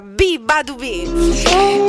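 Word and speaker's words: beabadoobee: Però è B-E-A, Badu D-O-O-B-E. E si pronuncia B, Badu B.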